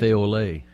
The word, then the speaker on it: café au lait: Well, how do you say that? Café au lait? – Café au lait.